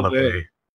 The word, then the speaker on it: LaVey: Oh, LaVey. – LaVey. Yeah. – LaVey. – It's okay. – Anton LaVey.